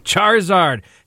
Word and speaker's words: Charizard: Charizard has sold at auction for a total of, Lisa, any guesses? – $10,000.